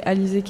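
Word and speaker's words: Alizée: Du coup, c'est Alizé qui est là. Mon prénom et mon nom. Ça s'écrit A-L-I-Z-E-E.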